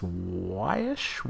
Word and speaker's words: Waesch: Soccer adds Auburn transfer, Hannah, I believe you pronounce this Wash, Wash, Wash, W-A-E-S-C-H, Wash, Wash, to the program.